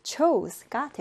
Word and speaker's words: chose: 자, 발음 같은 경우엔 현재형은 U로 발음해주고, 과거형은 O로 발음해주면 됩니다. 그래서 choose, chose, choose, chose가 되겠습니다.